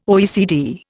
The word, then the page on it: OECD: Indian